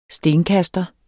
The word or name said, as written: STENKASTER